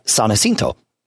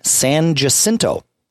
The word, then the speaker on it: San Jacinto: It is spelled S-A-N space J-A-C-I-N-T-O. San Jacinto is the name of that particular person, saint. Everybody in Austin calls that street San Jacinto.